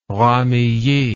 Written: Ramillies

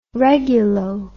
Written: regulo

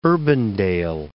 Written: Urbandale

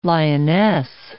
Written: Lyonnesse

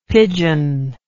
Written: pidgeon

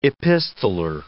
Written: epistoler

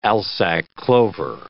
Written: alsike clover

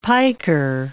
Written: piker

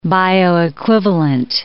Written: bioequivalent

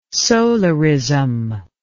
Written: solarism

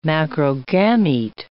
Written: macrogamete

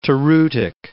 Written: toreutic

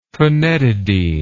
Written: phenetidine

